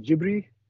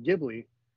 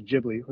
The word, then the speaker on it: Ghibli: So I know, how do I say, like, phonetically it's Jibri, but when I watched the House of, was it Madness or whatever the documentary was, because I always said it with a hard G, I always said Ghibli, and then I heard him in interviews saying with the soft G,